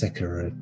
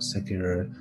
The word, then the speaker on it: Sekiro: And genuinely becoming a little more excited each time, because I think it is learning from Sekiro as well. I'm saying it wrong. It's Sekiro, not Sekiro.